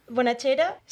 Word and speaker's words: Bonachera: Bonachera, soy de Roquetas de Mar en España y no sé, estoy aquí haciendo comedia en Inglaterra.